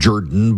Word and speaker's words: Jordan: So two Democrat progressives ran for office, Miracle Rankin and Jen Jordan, who pronounces her last name Jordan because she's from south of Atlanta and that's what they do.